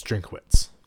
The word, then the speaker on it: Drinkwitz: Is it's like is you it, think it'd be Drinkwitz. But it's not. – It's from all that I've heard pronounced, it's Drinkwitz.